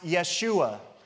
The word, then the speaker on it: Yeshua: So the name Jesus in Hebrew is pronounced Yeshua.